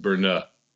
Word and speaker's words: Brno: It's apparently the standard gargoyle look in Brno, which I've actually been told is actually pronounced Brno.